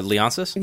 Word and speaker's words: Leonsis: Leonsis? – Yeah.